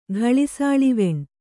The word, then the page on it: ಗಳಿಸಾಳಿವೆಣ್: gaḷisāḷiveṇ